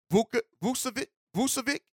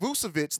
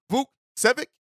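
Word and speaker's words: Vukčević: but it's Tristan Vukcevic. I think that's how you say, not Vucevic, like the Bulls player, but it's Vukcevic, I think is how you pronounce it.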